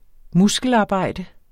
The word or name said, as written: muskelarbejde